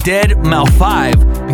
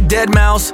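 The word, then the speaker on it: deadmau5: Now, for weeks, I've been saying Dead Mouse 5 because that's how it's spelled, okay? Dead Mouse, and then it's got a 5. But it's actually Dead Mouse so this is my public apology to Dead Mouse for mispronouncing the name, man.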